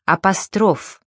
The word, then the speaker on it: апостроф: Правильно произносить «тефтель», «апостроф», «иконопись».